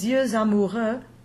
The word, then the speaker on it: yeux amoureux: In the example that Nathalie created, les yeux amoureux, the loving eyes, you'll hear that X from Y-E-U-X, yeux, you hear that X in liaison. But now it's not an X as we would pronounce it. Phonetically, X becomes a Z in liaison.